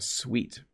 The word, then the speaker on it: suite: When you go to a hotel, you might stay in a suite. So this is actually pronounced the same way as, you know, sugar is sweet. That's S-W-E-E-T. But a suite is a small room or collection of rooms in a place like a hotel.